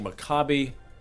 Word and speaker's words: Maccabi: Well, it turns out the Hebrew pronunciation is Maccabi, not Maccabi. So it's they're playing Maccabi Ra'anana.